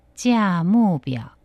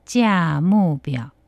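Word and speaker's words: 价目表: 가격표, 요금표, 차림표 얘기할 때는 짜무벼, 짜무벼, 짜무벼.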